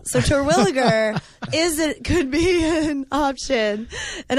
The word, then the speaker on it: Terwilliger: I've always pronounced it Terwilliger. – Good job. – That is correct.